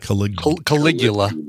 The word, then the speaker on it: Caligula: They're just not in their top 10, but Caligula. Rather, how do you say that? Caligula. There you go.